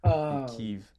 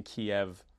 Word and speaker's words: Kyiv: I recently learned the Ukrainian way to pronounce Kyiv. – Kyiv, after saying Kiev for my entire life.